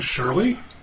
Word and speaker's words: Shirley: My name's Bob Shirley, and that's spelled exactly like a girl's name.